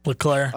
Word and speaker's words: Leclerc: Charles Leclerc, is that how that is pronounced? Leclerc? – Leclerc.